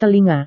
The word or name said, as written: telinga